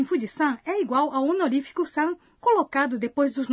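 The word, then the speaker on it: San (山): No entanto, a pronúncia muda para... – San. – Quando essa palavra é colocada depois do nome de algumas montanhas.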